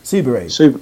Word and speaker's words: Subaru: Subaru.